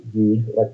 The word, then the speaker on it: Gui: His name is Guy Hurst, spelled Guy, G-U-I, but I think he pronounced it Guy.